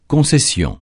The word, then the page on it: concession: francais